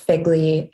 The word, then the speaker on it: FGLI: Yeah, it was interesting to a lot of us in it's pronounced Figley, FGLI, which is the umbrella term for first generation and or low income students.